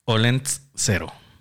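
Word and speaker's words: Olentzero: Olent cero. Así se llama. Olent cero. Olent cero. Sí. Así tal, tal como se escribe.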